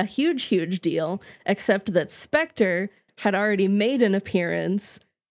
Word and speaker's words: SPECTRE: Yes, Spectre.